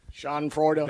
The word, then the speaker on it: Schadenfreude: Schadenfreude. – That's exactly what it is. Very good, by the way. Very good pronunciation on that.